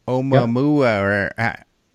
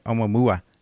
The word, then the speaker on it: Oumuamua: Tabby Star, Oumuamua, I think that's how you say it. Oumuamua. It's fun to say. Yours probably, I think it's even Polynesian or something, so you're probably supposed to say Oumuamua.